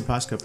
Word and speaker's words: Posca: It's mostly Posca paints. – Yeah, right? Am I saying that right? – Yep.